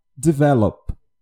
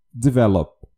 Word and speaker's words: develop: O que eu observo é que muitas pessoas acabam pronunciando incorretamente o verbo develop, que é desenvolver. Então, observem que a sílaba tônica ela está no começo do verbo develop. Develop.